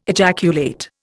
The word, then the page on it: ejaculate: Indian